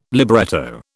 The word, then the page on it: libretto: British English